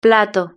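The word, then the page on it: plato: Spanish (Spain)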